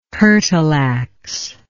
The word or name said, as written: curtle ax